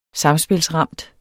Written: samspilsramt

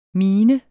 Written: min

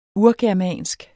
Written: urgermansk

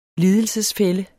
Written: lidelsesfælle

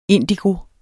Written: indigo

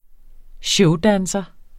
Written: showdanser

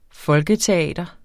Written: folketeater

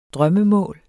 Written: drømmemål

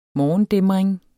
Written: morgendæmring

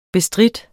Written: bestride

